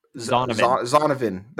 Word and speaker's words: Zonovan: How do you say his first name again? – I'm not sure. Let's see. I don't remember. – Zonovan. That's Zonovan it. Yeah, Knight. Zonovan Knight. They've